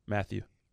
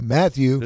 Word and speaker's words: Mathieu: And Mathieu, how does he say his name? – Mathieu. – Mathieu? – Yeah. – Okay, Mathieu.